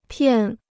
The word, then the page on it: 骗: piàn